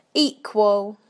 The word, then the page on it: equal: British English